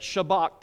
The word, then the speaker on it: shabach: I'm going to say it shabak today. It's spelled S-H-A-B-A-C-H.